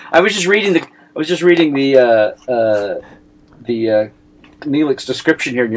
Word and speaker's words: Neelix: Neelix, pronounced Nylix.